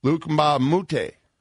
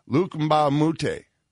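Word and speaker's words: Luc Mbah a Moute: They lost their best defender in Trevor Ariza to free agency, and they also said goodbye to Luke Mbamute. I love saying that correctly. I'm so proud of myself. Luke Mbamute. It's not easy.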